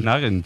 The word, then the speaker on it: Nugent: Yo era de Ted Nugent. – Pero la pronunciación correcta es Nugent. – Te fuiste a Washington y descubriste que era Nugent.